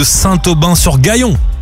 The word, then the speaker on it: Saint-Aubin-sur-Gaillon: En live de Saint-Aubin sur Gaillon. – C'est ça. – Dans le 27, je l'ai bien dit, c'est comme ça qu'on prononce Saint-Aubin-sur-Gaillon. – Tout à fait, tout à fait.